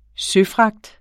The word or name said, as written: søfragt